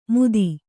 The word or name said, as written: ಮೂತಿ